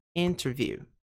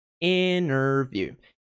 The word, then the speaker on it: interview: Então, a nossa sílaba tônica aqui é interview, interview, interview, interview. Tá? Interview, interview, interview. Tá? Não é ter.